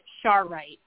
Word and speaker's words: Charoite: So the crystal, if you have a pen, it's spelled C-H-A-R-O-I-T-E. Okay? – I believe, okay. Mary, it's, they pronounce it Charite.